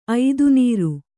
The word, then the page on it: ಐದುನೀರು: Kannada